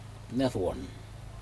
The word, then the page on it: nedawun: Aneityum